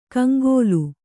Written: ಕಂಗೋಲು

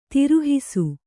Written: ತಿರುಹಿಸು

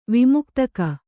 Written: ವಿಮುಕ್ತಕ

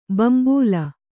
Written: ಬಿಂಬಲಿ

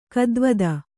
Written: ಗೀತಿವಿದ

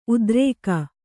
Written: ಉತ್ತರಿಗೆ

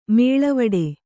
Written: ಮೇಲ್ವಟ್ಟ

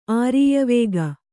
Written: ಆರೀಯವೇಗ

